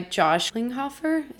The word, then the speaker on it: Klinghoffer: Anyways, so then after that, John Frusciante was replaced by Josh Klinghoffer.